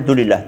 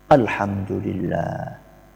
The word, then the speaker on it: Alhamdulillah: Banyak orang ketika membaca itu enggak sempurna, karena pengen cepat selesai, maka dia bacanya Dulillah, Dulillah, Dulillah. Apa itu Dulillah?